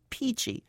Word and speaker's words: pici: The restaurant was Fresco Italian Cafe, and the dish was a Tuscan pasta called Peachy. That's spelled P-I-C-I.